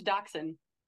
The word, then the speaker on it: dachshund: No, deshunned, like a wiener dog. – Yeah, that's dachshund. – Oh, what's a deshunned? What? – That the word you're saying is pronounced dachshund. – How do you spell it? – D-A-U-S-C-H something, you know, the same word.